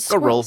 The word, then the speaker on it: squirrel: Yeah, but squirrel. – Squirrel. – Like, it rhymes with Professor Quirrel.